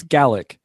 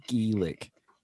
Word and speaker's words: Gaelic: Because if you're from Scotland, it's Gaelic. Only Irish people say Gaelic.